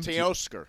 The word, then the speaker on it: Teoscar: How do you pronounce his first name? – Teoscar.